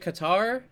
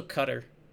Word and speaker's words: Qatar: So, you can pronounce it Qatar, but it's also Cutter.